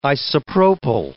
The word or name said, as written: isopropyl